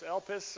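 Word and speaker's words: elpis (ἐλπίς): But it is the one time in the Bible when this Greek word, which is almost always translated hope, the word, it's roughly pronounced elpis or elpis, but that word elpis, that Greek word for hope, listen to Hebrews 10, 23.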